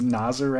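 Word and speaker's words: Nazaré: Only because two or three years ago, people started correcting us and it was Nazir or something closer to Nazir.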